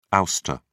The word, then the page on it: ouster: British English